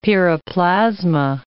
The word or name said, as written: piroplasma